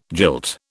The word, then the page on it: jilt: British English